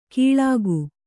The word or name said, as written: ಕೀಳಾಗು